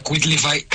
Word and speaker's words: Quitlify: Se pronuncia Quitlify. Quitlify. – Quitlify. – Esta Quitlify, como de quit.